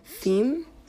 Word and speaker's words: PHEME: The European Union is also investing in a technology called FHEME. It's spelled P-H-E-M-E, which hopes to better understand and automate the question of veracity when it comes to online and user-generated content.